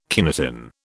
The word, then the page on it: kinetin: British English